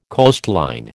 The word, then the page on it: coastline: American English